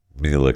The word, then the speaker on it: Mila: So I see this post from Ashton Kutcher and Mila Kunis. It's Mila, right? It's Mila, right. That's how she wants it pronounced.